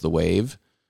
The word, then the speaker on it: Waeve: and they call themselves The Wave, but they spell it W A E V E.